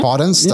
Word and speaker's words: Podence: Podence. That's how you say his name.